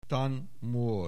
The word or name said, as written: Than Mor